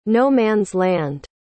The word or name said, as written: no-man's-land